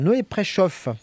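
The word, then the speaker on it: Noé Preszow: Noé Préchoff, ça s'écrit à P-R-E-S-Z-O-W.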